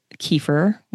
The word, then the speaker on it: kefir: kefir, which it's really pronounced kefir, but most Americans say kefir.